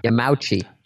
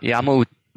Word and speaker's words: Yamauchi: Yamauchi. – No, Yamauchi. So Yama, which is mountain, Uchi.